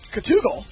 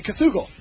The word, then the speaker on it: Cthugal: It is called Cthugal. – Cthugal? – Just like Google. – Only Cthugal.